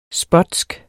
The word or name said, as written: spotsk